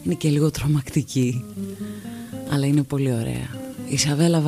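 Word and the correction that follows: Chavela: Βάργκας, η Τσαβέλα Βάργκας, να μας πει ο Ιούλιος πώς διαβάζεται.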